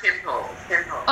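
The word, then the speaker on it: Tympole: Oh no, I do need like Tynapole, I don't know how you pronounce it. – Yeah, I do have, I have Timpole. – Oh, Timpole, yeah, thanks.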